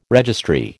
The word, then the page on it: registry: American English